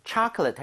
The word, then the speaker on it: chocolate: chocolate 할 때는 앞에 O의 강세가 가죠. 그러면서 입 모양이 좀 벌려지겠죠. 예. So, 초콜릿보다는 chocolate에 가깝고요.